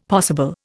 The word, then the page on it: possible: Indian